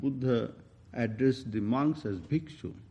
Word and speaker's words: bhikshu: Sanskrit word, actual word is bhikshu, which has been in Pali been pronounced as bhikkhu.